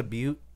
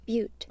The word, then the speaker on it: butte: And go ahead, there's a little noise icon next to butte. – Click that? – Butte. – Oh, butte. – Yep. – So that is how you pronounce it.